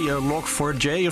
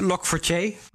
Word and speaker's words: Log4j: Ja, zo is hoe, hoe, het wel werd uitgesproken, Log4j. Oké. Ja, als je, als je zegt eh, log 4J vind ik het ook prima hoor. Ik eh, vind het allebei oké.